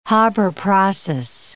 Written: Haber process

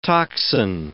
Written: toxin

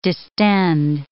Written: distend

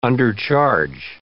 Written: undercharge

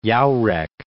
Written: Wagner-Jauregg

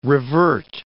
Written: revert

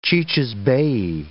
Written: cicisbei

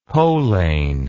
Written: poleyn

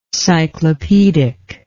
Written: cyclopaedically

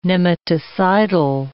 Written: nematicidal

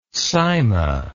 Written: sima

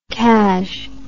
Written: cas

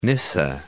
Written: Nysa

